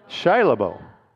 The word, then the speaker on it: Shia LaBeouf: I guess it's Shia LaBeouf, is that right? Shia LaBeouf.